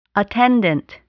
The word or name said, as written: attendant